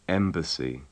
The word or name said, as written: embassy